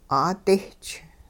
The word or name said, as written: 'aadisch